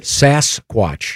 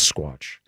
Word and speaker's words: Sasquatch: Am I pronouncing it right now? – Yeah, you did. – Sasquatch. – That's right. That's correct. – Not Sasquatch. Yeah. That sounds a little too East Coast.